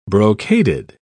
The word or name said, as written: brocaded